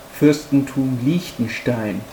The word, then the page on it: Fuerstentum Liechtenstein: German